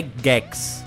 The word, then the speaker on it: Gex: As pessoas chamam de Gex. O nome, a pronúncia correta é Gex.